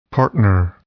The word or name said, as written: partner